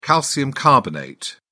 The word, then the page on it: calcium carbonate: British English